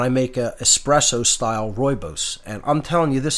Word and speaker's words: rooibos: Rooibos, it's spelled R-O-O-I-B-O-S, pronounced rooibos. Looks like rooibos, but it's pronounced rooibos, and it's just, it's fantastic.